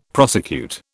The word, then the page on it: prosecute: British English